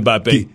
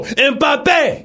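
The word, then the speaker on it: Mbappé: Mbappe. So if he beats Messi. – You don't really say the M.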